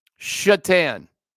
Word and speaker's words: Satan: The one guy, when I worked for the Buffalo Sabres, his last name was spelled S-A-T-A-N. Take a shot at that one. How would you pronounce S-A-T-A-N? Mm-hmm. Yeah. Shatan. Not Satan. Was not pronounced Satan. It was Shatan.